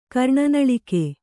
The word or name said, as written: ಕರ್ಣನಳಿಕೆ